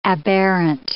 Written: aberrant

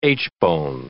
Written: aitchbone

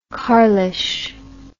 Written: carlish

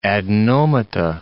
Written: adenomata

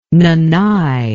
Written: nanay

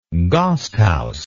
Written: gasthaus

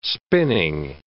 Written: spinning

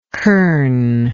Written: curn

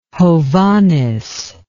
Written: Hovhaness

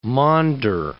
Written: maunder

